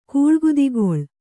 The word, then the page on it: ಕೋಳ್ಗುದಿಗೊಳ್: koḷguḍigoḷ